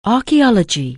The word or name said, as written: archeology